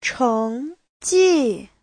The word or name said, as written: cheng2ji4